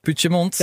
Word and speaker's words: Puigdemont: Puigdemont, ja, ik inderdaad.